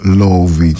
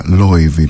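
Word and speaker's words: Laufey: Η Λόουφη λοιπόν, που στην πατρίδα της στην Ισλανδία την διαβάζουν ως Λόουβη και μάλιστα Λόιβη πιο σωστά,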